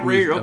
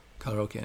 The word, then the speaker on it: karaoke: Karaoke is what karaoke. Americans say, but karaoke is what they say in Japan.